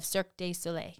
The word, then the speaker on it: Cirque du Soleil: Watch and wonder as the beauty of Disney animation blends with the thrilling spectacle of Cirque du Soleil.